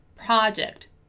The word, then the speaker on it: project: Here we have, once again, another word that has that special O pronunciation, the word project. So once again, ah, ah, ah, project, project, project.